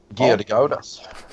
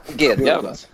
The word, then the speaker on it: Gedgaudas: i, beroende på genre och stil och Daniel Gidgaudas. – Um, ehm, Ja. Jag blev mest imponerad av att du uttalar hans efternamn. Alltså, det var... – Gedgaudas. – Gedgaudas? – Ja, nej, ja.